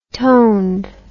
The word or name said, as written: toned